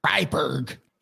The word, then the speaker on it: Freiburg: The Freiburg Shrieker. Am I right in that pronunciation? I wonder. – I do not speak German, but that sounds right. – Freiburg.